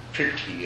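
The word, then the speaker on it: prīti: So this whole process is named piti in the Theravada system, and it's actually named piti, pronounced slightly differently in Sanskrit. Pirti is the way it's pronounced in Sanskrit.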